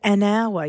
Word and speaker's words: hour: And in hour, you don't really pronounce the H.